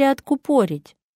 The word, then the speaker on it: откупорить: Откупорить или откупорить? Правильный ответ – откупорить.